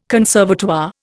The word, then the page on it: conservatoire: Indian